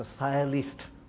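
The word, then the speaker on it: sciolist: It's called the pronunciation is a silist. A silist. Silist.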